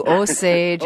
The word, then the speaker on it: Osage: Thank you, Osage.